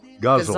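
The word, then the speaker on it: ghazal: Okay, so guzzle. Guzzle. – Okay, got it. Guzzle. – Pronounced guzzle. Guzzle. – It sounds like a foreign city, guzzle, but it's not pronounced that way.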